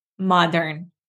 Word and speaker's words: modern: Modern. – There we go. Modern. So the first vowel sound is ah, ma, modern.